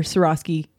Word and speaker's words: Swarovski: I need to make a competitor for Swarovski crystals.